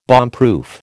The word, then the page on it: bombproof: American English